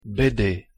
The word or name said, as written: BD